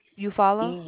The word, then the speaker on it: Eufaula: Lunsford, L-U-N-S-F-O-R-D. – Spell that one more time for me. – L-U-N-S-F-O-R-D. Street. Eufaula. – Eufaula? – E-U. Yes. It's E-U-F-A-U-L-A.